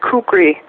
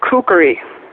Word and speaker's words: kukri: it's not kukri, it's kukri. Kukri, 3 syllables, okay?